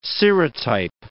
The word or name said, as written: serotype